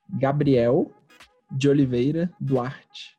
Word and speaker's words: Gabriel de Oliveira Duarte: Gabriel de Oliveira Duarte.